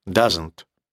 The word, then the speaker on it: doesn't: Второе «не» для настоящего времени – doesn't. То есть там в серединочке есть «с». Doesn't. Просто произносится как «з».